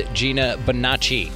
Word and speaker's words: Gina Bonacci: Welcoming to the show, it is Gina Bonacci.